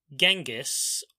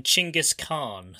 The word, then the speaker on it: Chinggis Khan: Yes, and if you haven't figured out who I'm talking about, the person I am talking about is Genghis, or to give him the more like I guess authentic pronunciation, Chinggis Khan.